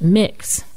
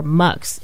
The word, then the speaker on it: Mx: How do you pronounce it? And they told me, you pronounce it Mix or Mux. It used to be Mux, but they didn't like the way that sounded, so it became Mix.